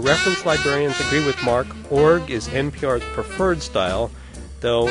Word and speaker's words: org: Since its creation in the fall of 1984, people in the know have pronounced ORG as a single syllable org, the first syllable of organization.